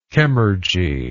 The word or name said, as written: chemurgically